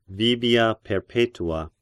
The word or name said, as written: Vibia Perpetua